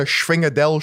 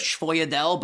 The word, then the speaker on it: sfogliatella: But every time you try to order, hey, can I get a Schwingadel, Schwoidel? They hit you with like a, how do you say it? – I'm pretty sure it's Schwoidel, but I'm basing that off of The Sopranos.